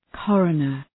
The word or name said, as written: coroner